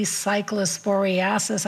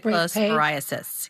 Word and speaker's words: cyclosporiasis: If you are worried, there is a CDC cyclosporiasis. I didn't pronounce that right. – Outbreak cyclosporiasis. Page.